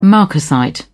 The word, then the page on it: marcasite: British English